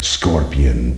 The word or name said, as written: Scorpion